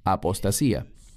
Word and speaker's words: apostasía: La palabra aquí es apostasía, en griego, que se pronuncia igual, apostasía.